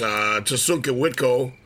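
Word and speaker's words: Tȟašúŋke Witkó: Or, approaching the actual pronunciation is Tasunka Witko, which actually means his horses are crazy, is the closest I've been able to find.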